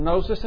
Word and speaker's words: gnosis: We just got a quick lesson on gnosis and epignosis. Why is it that knowledge in both Greek and English has a silent letter at the beginning. Who thought that up? Gnosis. G-N-O-S-I-S. Where did that come from? Knowledge. K-N-O-W-L-E-D-G-E.